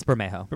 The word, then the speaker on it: Bermejo: Is it Bermejo or Bermejo? – It's Bermejo. – Bermejo.